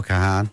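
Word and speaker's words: Kahan: Noah Cahan.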